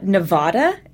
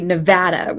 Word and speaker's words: Nevada: It's not Nevada, as Kerry had said, you know, just before the time I was taking this trip. You know, it's actually Nevada with, you know, that short A, like as flat an intonation as possible.